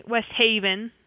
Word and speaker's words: West Haven: Now, here's how they say it. They're like, if you say, where do you live? They're like, West Haven. It's like emphasis on the haven.